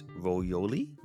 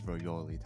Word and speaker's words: Royole: I'm gonna spell it for you guys. R-O-Y-O-L-E. So I'm thinking it's Royoli. – I think it's Royoli too.